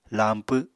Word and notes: lañpw: apatani

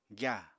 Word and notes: gyàa: apatani

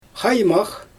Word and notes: xajmax: Xyzyl

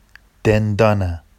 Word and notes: dendɑna: Vafsi

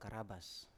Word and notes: karabas: Biak

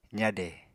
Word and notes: zade: apatani

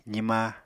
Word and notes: zima: apatani

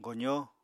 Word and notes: gozó: Apatani

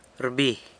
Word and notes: rbi: sanlucasquiavini

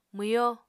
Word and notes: mwyo: Apatani

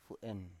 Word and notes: fu7en: Biak

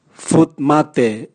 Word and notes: vut mate: Raga

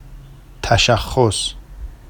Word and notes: taʃaxxos: Vafsi

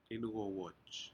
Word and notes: incowaj: Aneityum

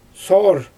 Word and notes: soowr: Xyzyl

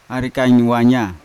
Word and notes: arikain wanya: Yawa; Unat

